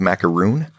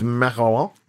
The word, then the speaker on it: macaron: I'd never had one because I was confusing with macaroon, which I think a lot of people do. I hope a lot of people do. – Is macaron? – Macaron. – Is that the appropriate pronunciation or is that the plural of macaron? – Macaron. Well, it's both.